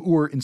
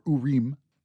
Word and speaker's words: Uri: The other sign, Uri, is the name of Ur in Sumerian, pronounced either Uri or Urim.